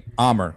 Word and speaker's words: Amer: Amer.